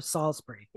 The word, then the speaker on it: Salisbury: They also said Salisbury instead of Salisbury.